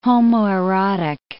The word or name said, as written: homoerotic